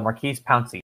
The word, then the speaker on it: Maurkice Pouncey: Uh, so it was uh, Maurkice Pouncey.